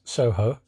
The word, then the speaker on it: Soho: They're not pronouncing it weirdly anymore. Now they're pronouncing it as Soho.